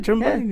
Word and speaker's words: Tremaine: You said Tremblaine, and I was like, I went with it. – yeah, because like, oh, it sounds right. – Tremaine. Yeah. I added a little syllable in there just for you.